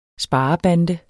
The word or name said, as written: sparebande